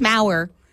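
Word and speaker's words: Maur: I have been pronouncing her name wrong this entire time because I always said Melissa Oftermauer. And then I listened to her audiobook, and she's, I mean, I think she knows how to say her own name. It's Maurer. Rhymes with hour.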